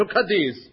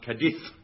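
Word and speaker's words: Cádiz: Oh, why don't you send him to Cadiz? – It's pronounced Cadiz.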